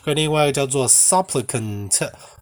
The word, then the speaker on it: supplicant: supplicant 是 s u p p l i c a n t。 S U P P L I C A N T，supplicant。那我刚刚讲的那个 suppliant，它的发音不一样，它是 suppliant，它也是恳求的人，所以恳求的人这个字就有两个字跟两个发音。